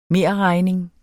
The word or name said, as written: merregning